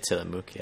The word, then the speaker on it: Tillamook: Tillamook. – I would say Tillamook, yeah.